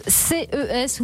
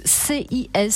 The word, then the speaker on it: CES: Oui, du 5 au 8 janvier prochain, ce sera le CES ou le CIS, selon comment tu prononces ça, à Las Vegas.